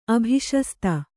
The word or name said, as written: ಅಭಿಶಸ್ತಿ